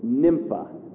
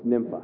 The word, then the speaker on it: Nympha: Say it with me, Nympha. Not pronounced any other way, it's Nympha.